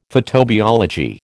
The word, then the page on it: photobiology: American English